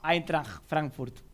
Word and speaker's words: Eintracht Frankfurt: Qué buena pronunciación. Eintracht Frankfurt. No, de verdad, dice así, no me cargue.